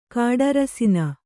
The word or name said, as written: ಕಾಡರಸಿನ